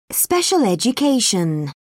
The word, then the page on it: special education: British English